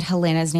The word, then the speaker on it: Helena: But we have opted to use the Swedish pronunciation, which is like adding the like Helena and Christina. So if you hear that, then that's what that means.